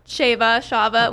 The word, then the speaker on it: Shaavvaa: I go by Shaavvaa, okay. However you feel to pronounce it, it's totally fine.